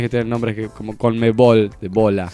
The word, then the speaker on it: Conmebol: Y queda una sigla hermosa, Conmebol. – Es muy buena. – Muy Conmebol. Pronunciable, nada de cómo la pronuncio, C-O-M-B-B-O-L, no. – Hay que hacer Conmebol. – Así. Hay que hacer así.